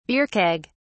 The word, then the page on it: beer keg: British English